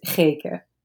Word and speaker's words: Geke: Yes, my name is Geke. It's a really Dutch name.